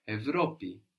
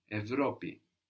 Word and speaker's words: Ευρώπη: To see an example of it pronounced like V, we said this is less common, but it still happens. We have Europe, Ευρώπη, Ευρώπη. – Ευρώπη.